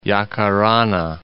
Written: Yacarana

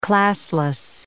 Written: classless

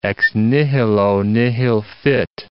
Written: ex nihilo nihil fit

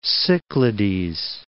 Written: Cyclades

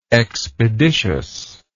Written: expeditiousness

